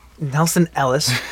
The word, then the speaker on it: Nelson Ellis: Nelson Elias, I believe that's how you say his name. – Ellis, dude. – Nelson Ellis. It could be Elias. – It's E-L-L-I-S. – L-I-S. Oh L-I-S.